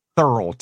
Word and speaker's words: Thurl: Thurl, T-H-U-R-L. Thurl Ravencroft sang the original Grinch song, you know, from the cartoon. – Yeah, yeah. – And what else was Thurl Ravencroft known for?